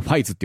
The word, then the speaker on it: 555: ど、 2003 年にやっていたあの平成仮面ライダーシリーズ第 4 作ですね、仮面ライダーファイズの 2 回目のエンディングテーマです。何回か変わってるんですけど、このファイズってあの 555 と書いてファイズって読むんですよ。